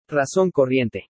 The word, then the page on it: RAZON CORRIENTE: spanish